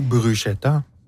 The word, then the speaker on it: bruschetta: aux tomates ou bruschetta? – C'est vrai que ça se rapproche de la bruschetta quand même. – Parce que vous le prononcez mal. Ouais. Bruschetta.